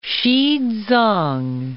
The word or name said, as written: Xizang